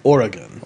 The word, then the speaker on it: Oregon: It's the emphasis on the or. So it's Oregon.